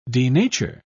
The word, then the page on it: denature: British English